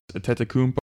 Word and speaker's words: Antetokounmpo: This is a guy who also reps Rudy Gay and the Greek freak from Milwaukee, Giannis Antetokounmpo.